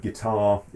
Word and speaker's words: guitar: the word guitar is a two-syllable word, and the strongest syllable is the final syllable, which means that guitar rhymes with car, but not quite with caviar, although people often go caviar in order to make those accommodations, and most lyricists at some point or composers find that.